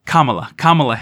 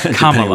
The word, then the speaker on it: Kamala: And because of that, they were able to, sorry, I think I mispronounced, it's Kamala Harris. That's her name, for some reason, gets me every time, but I didn't even notice that you pronounced it differently in those two times, so I'm probably saying it wrong. – There's like four ways to pronounce her name, and obviously only one of those is right. It's Kamala. – Which A you emphasize.